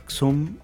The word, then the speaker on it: Xum: Mux, Xum, si no lo pronuncio mal, X-U-M.